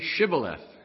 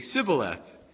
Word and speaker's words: Shibboleth: If he said no, then they would say to him, then say Shibboleth. This is funny. And he would say Sibboleth, for he could not pronounce it right.